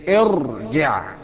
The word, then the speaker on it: ارجع: طيب حاجة تانية، كلمة زي ارجع، ننطقها ازاي؟ برضو بالكسر، ما احنا لسه قايلين التالت اذا كان مفتوح او مكسور نبدأ بالكسر. خلصنا المفتوح زي اذهب، يبقى ده الايه؟ المكسور، نقول ايه؟ ارجع.